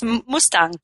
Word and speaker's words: Mustang: Am I pronouncing that right? – Yeah, actually, it's Mustang.